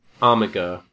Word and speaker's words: Armiger: Armiger or Armiger? – Armiger. Haji. – Armiger. Yeah. Okay.